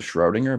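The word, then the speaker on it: Schrödinger: Everybody calls him Schrodinger, but I'm pretty sure you're really supposed to pronounce it Schrodinger in German because of the two little dots.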